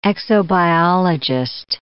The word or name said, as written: exobiologist